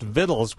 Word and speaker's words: victuals: Her new book is Vittles, spelled like victuals, but that word is pronounced Vittles, we have learned, an Appalachian Journey with Recipes.